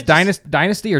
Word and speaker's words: dynasty: Is it should I pronounce it as dynasty or dynasty? – Dynasty. – Dynasty. – We're not English. – Well, I wanted to be like a historian. – We speak dynasty. We speak American around here.